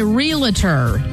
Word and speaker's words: realtor: A few people actually said realtor, which a lot of people say realtor. – Is it Realtor? – It's Realtor. – Realtor. – Your Realtor. It's R-E-A-L-T-O-R.